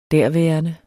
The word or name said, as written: derværende